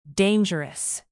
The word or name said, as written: dangerous